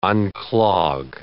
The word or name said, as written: unclog